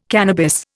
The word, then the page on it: cannabis: Indian